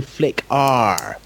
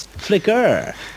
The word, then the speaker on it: Flickr: Yeah, because for the past 38 podcasts, it's always been Flick R from you. But now, all of a sudden, it's Flicker.